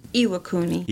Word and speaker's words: Iwakuni: Iwakuni.